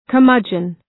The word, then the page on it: curmudgeon: kər’mʌdʒən